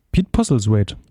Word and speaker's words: Pete Postlethwaite: Wie wird er ausgesprochen? – Pete Postlethwaite. – Postlethwaite. Postlethwaite. Ja, genau, aber seinen Rollenname habe ich mir aufgeschrieben als David Postlethwaite, was witzig wäre.